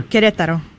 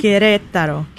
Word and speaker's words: Querétaro: Querétaro.